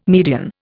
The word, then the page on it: median: Indian